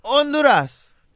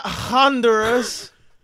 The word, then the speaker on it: Honduras: It's Honduras. – Yeah. – The H is silent. It's not Honduras.